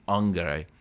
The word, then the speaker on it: angere: Now, what's interesting about unctuous is it comes from the Latin verb angere. Well, if you fall in classical pronunciation, angere.